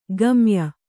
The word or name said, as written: ಗಮ್ಯ